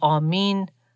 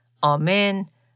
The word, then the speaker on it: آمین: کلمه بین المللی که فقط تلفظش در زبانهای مختلف متفاوته. و مثلا به صورت آمین، آمن و یا ایمن تلفظ میشه.